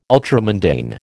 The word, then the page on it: ultramundane: American English